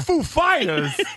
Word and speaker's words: Foo Fighters: And Dave Grohl basically goes, well, I know how he talks, so I said, Christopher Walken said to Dave Grohl, is the accent on the Foo or on the Fighters? And he said, it's on Fighters, because he knew he'd go, Foo Fighters, which is exactly what he did.